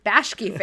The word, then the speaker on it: Bakshi: How do you say his name? – Bashki fan.